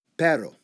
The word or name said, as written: perro